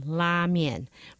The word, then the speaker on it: la mian: You know, in Chinese, it's pronounced la mian. But when it went to Japan, it became R-A-M-E-N.